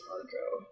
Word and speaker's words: Arco: I think it's Argo. A-R-C-O. Yes.